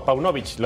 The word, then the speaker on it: Paunovic: A Paunovic, ¿lo pronuncié bien? Perfectamente bien. Perfecto.